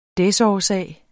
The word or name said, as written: desårsag